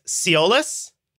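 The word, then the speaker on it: Tsiolis: So it's spelled T-S-I-O-L-I-S. I think it's Siolis. – Siolis sounds fine to me.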